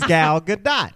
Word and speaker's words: Gal Gadot: I have not, because that's not how her name is pronounced. She says Gal Gadot.